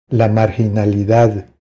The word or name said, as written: La marginalidad